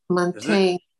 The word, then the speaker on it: Montaigne: Montaigne.